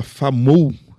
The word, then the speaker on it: Famou: Uh, que se chama Famou, é, é, certo, né, a pronúncia? – Isso.